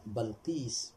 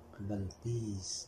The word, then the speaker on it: بلقيس: المعروف المحفوظ بفتح الباء بلقيس. ما هل هناك لغة بكسر باء؟ لا. نعم. لكن المشهور بفتح الباء. نعم. بلقيس.